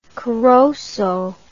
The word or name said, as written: corozo